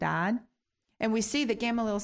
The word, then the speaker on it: Gamaliel: And around 14 years old, his father sent him into Jerusalem to learn under a man named Gamaliel, G-A-M-A-L-I-E-L, Gamaliel. And you can actually find Gamaliel. Oh, no, I'm saying it wrong. Oh, I thought you... Gamaliel. We can see about Gamaliel in the book of Acts.